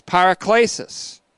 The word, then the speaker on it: paraklesis: Paraklesis is P-A-R-A. K-L, long E-S-I-S, A to E-S-I-S, paraklesis.